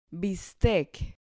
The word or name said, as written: bistec